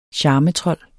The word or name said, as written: charmetrold